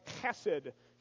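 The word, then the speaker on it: chesed (חסד): Now, you have to pronounce this with the good, you know, Jewish accent, and it's something like chesed, okay? It's kind of like German. You got to get a little phlegmy, a little in there.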